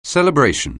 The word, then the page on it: celebration: anglais